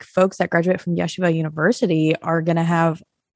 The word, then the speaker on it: Yeshiva: Is it Yeshua how you say it? – It's Yeshiva University. – Why? Yeshiva. Thank you.